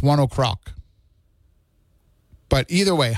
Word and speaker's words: One OK Rock: I've seen a lot of reviews of them on YouTube, people doing like those listening reaction videos, and they refer to them as One OK Rock. But when you go to the band's website, it says that it's pronounced 1 o'clock. And it says, it's like you're saying 1 o'clock with a Japanese accent.